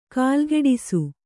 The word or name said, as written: ಕಾಲ್ಗಿಡಿಸು